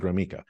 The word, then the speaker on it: Gromicko: How do you, there's no E in it, but I pronounce it Gromyko.